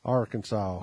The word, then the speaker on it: Arkansas: Is it pronounced Arkansas or Arkansas? Yes, Arkansas.